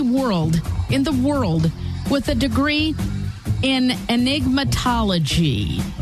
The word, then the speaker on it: enigmatology: Enigmatology, like an enigma.